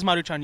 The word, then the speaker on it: Maruchan: No, it is Maruchan. You said it right.